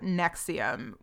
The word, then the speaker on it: NXIVM: I know that it's, like, a slow thing, but, like, so anyway, we're going to talk about NXIVM, which is a cult that was, it's spelled NXIVM, but, or NXIVM, NXIVM, the V is pronounced like a U, I guess.